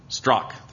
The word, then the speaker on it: Strauch: In Alexander Strauch, did I say his name right? Strauch? Strauch? Strauch? Strauch, thank you.